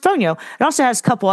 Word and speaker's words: Fonio: Fonio. Pronounced like the telephone, phone, and then like the yo-yo, yo, Fonio.